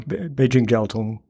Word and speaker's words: Beijing Jiaotong: Beijing Jiaotong University.